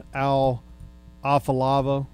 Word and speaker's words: Afalava: Al Afalava. Is that pronounced right? – Close enough.